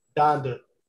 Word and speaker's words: Donda: Donda.